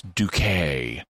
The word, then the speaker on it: Ducat: But her name is spelled D-U-C-A-T, and it, like the unit of money in some European nations. And so the doctor initially, more than once, pronounces her name Ducat. But then, but then it's like, oh no, it's Duquet. and that is very similar to, I think it's Frances is her name, but there's a character on Keeping Up Appearances.